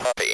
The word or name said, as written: puppy